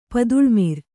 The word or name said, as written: ಪಾತಾಳಮರ